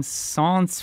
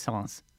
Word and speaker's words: Sens: Let's go to Sens, France. Is that how I pronounce it? – Very good job.